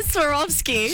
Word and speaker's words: Swarovski: Well, there was like 7,000 Swarovski, I can't say that word, crystals, apparently. – sorry, what? – Swarovski. How do you say it? – Swarovski.